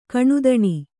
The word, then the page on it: ಘನತನ: ghanatana